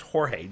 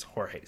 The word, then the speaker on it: Jorge: I think it's pronounced Jorge, J-O-R-G-E. I know a guy named George who spells it that way, but I think most people it's Jorge.